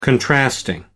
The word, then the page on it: contrasting: American English